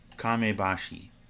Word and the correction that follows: Kamishibai: Am I pronouncing it right? Once again, people, I'm sorry. – Kamishibai, yes, thank you.